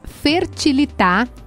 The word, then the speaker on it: Fertilitat: É @fertilitar. Se escreve Fertilitar, como se diz, mas tem um T no final. Então é FertilitatRS. @Fertilitat, com T mudo no final, RS. Tudo junto.